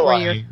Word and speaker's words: Ooloi: Eloy is how they say the auto. – Oh, how do they say it? – Eloy. – Eloy? – Yeah. Almost like Eloy from the H.G. Wells Time Machine. – Interesting. – Eloy. – Okay, because it's spelled O-O-L-O-I.